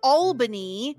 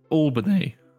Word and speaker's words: Albany: And of course, as always, I'm joined by someone who definitely knows how to pronounce Albany. How are you? – Albany. – Yes, thank you. – Albany.